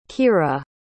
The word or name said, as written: kyra